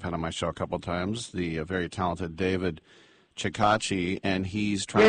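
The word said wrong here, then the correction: Chokachi: David Chokichi.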